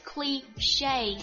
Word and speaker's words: cliché: 我们看下一句， cliché of the day c'est la vie that's just life，应了那句老话，这就是生活。我们看一下 cliché，它是一个法语单词，它不会就是按照我们平时的读音那样子，它读成了 cliché， cliché， cliché，它是陈词滥调或者老生常谈的意思，它是一个名词哦。我们看一下例句，遗憾的是古语说的那句久违情书通常都是事实。